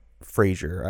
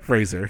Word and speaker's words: Fraser: I should say, how do you say it properly? – Fraser. – I can't. – Fraser. – Fraser, that's what it is. Yeah. I got to just imagine a Z there every time.